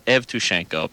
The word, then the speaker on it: Yevtushenko: It's now, the thing that's confusing, the way the Cyrillic and the Roman alphabets transliterate, he could be listed under Y for Yevtushenko or E for Evtushenko, because the E sound in Russian is sometimes pronounced Ye and sometimes pronounced E, but it's an E. It's written as an E.